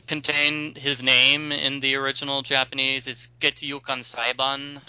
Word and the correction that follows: Gyakuten: Gyakuten. – Yeah, thank you. Gyakuten.